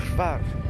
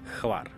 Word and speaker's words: Hvar: Var, uh, how do you pronounce? Hvar. Hvar. Hvar.